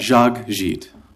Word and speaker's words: Jacques Gite: Jacques Gite. That's all. Two syllables, not too bad, you know? – Thank you very much.